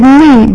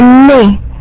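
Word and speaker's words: nne: And then I think for number 4, you said Ni, but you do not say Ni, you say Ne, Ne. And N-E is pronounced Ne.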